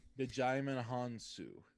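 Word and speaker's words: Djimon Hounsou: Dajimon Hounsou.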